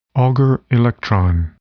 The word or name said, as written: Auger electron